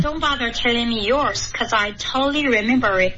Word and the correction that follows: totally: 啊就差了一个点 ,totally, 三个音节刚刚说的 totally, 就变成两个音节了